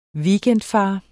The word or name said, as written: weekendfar